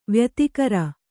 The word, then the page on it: ವ್ಯತಿಕರ: vyatikara